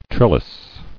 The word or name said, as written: Trellised